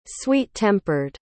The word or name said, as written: sweet-tempered